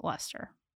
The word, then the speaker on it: Leicester: So, settling first in Newcastle, Leicester. I always want to pronounce that wrong, but they pronounce it Leicester. But it looks like Leicester or something like that, you know? – Yeah.